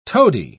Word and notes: tody: British English

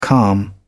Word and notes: calm: British English

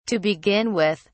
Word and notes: to begin with: British English

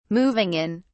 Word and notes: moving in: British English

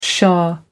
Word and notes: shah: American English

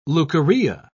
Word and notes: leukorrhea: British English

